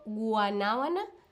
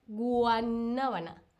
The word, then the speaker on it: guanábana: A ver, ¿tú alguna vez has comido guanábana? ¿Tú alguna vez has comido guanábana? Sí, y me encanta o no, nunca. Mm, mm. ¿ok? Esa es una palabra difícil de pronunciar, guanábana. Guanábana.